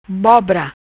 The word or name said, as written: Bobra